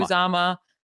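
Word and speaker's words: Uzomah: I know I always say his name Uzama. – Wrong. Uzama. I know it's two ways and I always go with the other way.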